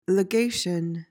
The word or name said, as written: legation